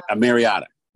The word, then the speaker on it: Mariota: So I didn't know that Moriata? Is that how we say his name? – Yeah, Mariota.